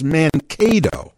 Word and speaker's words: Mankato: Many people were saying that the pronunciation of that is Mankato. – Mankato. Yeah. That's what we said.